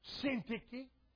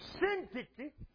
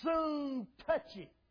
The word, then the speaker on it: Syntyche: And Syntyche, I read where Dr. H.A. Ironside used to mispronounce Syntyche just to get the emphasis across. He called her soon touchy.